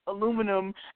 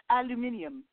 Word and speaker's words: aluminium: It reminds me of how one of my coworkers that's from the UK, he calls aluminum aluminium.